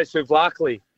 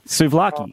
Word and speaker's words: souvlaki: I can't say souvlaki. – Oh, Christ. That's an easy one. – Go on. – Souvlaki. That's how you say it. – There's no A in the middle of it.